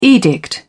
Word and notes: edict: British English

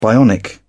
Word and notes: bionic: British English

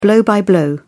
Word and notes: blow by blow: British English

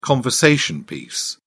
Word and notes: conversation piece: British English

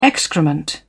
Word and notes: excrement: British English

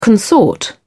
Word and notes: consort: British English

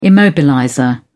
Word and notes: immobilizer: British English

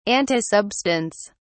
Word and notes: antisubstance: British English

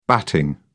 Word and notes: batting: British English

